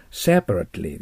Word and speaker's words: separately: その次、 separately ってなったらダメです。 separately です。 separately と発音します。